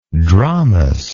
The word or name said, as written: dromos